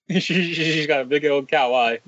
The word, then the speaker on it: kawaii: But she's just so kawaii.